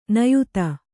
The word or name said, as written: ನಿಯತಿ